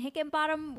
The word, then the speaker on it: Hickenbottom: We have Wayne Hickenbottom with us today, and I hope I said that right.